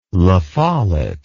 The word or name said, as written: La Follette